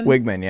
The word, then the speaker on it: Weigman: And I want to say it's Weigman, right? Connor Weigman? – Weigman, yeah. – I think it's spelled like Weigman.